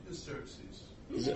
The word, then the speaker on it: Xerxes: How do you pronounce his name? – Xerxes. – Oh, we go with Xerxes, okay.